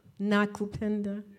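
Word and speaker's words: Nakupenda: And the theme of the conference that year was La Cupenda. Is that how you say it? Black love? – Na Cupenda.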